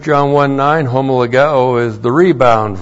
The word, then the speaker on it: homologeo: And then we have the verb homologeo with the negative me. H-O-M-O-L-O-G-E long O. Homologeo.